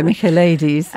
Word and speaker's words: Michaelides: I grew up in Greece. So for me, it was like, I know how to pronounce this name. I felt quite smug about that. – I'm so glad that you're here on the show. – Yes. – I shall defer to you in all Greek pronunciation. – Yes. Alex Michaelides. – Oh, there we go. Michaelides. All right. Yeah, it's good to have the right pronunciation.